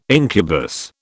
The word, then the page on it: incubus: British English